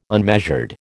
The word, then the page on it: unmeasured: American English